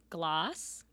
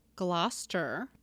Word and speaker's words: Gloucestershire: Nope. Gloss. – Gloss. Like gloss. Lip gloss. Gloss. – Tur, like turning on your turn signal. – Gloucester.